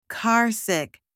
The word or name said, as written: carsick